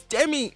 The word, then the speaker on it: Demi: And I'm like, no, say it right. It's Demi. It's so simple.